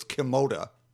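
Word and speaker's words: Kimota: It's atomic, pronounced backwards, so it's Kimoda.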